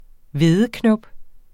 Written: hvedeknop

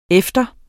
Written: efter

